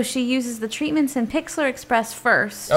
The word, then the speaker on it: Pixlr: Is that how you say it? – Pixlr. – Pixlr Express, which is P-I-X-L-R Express.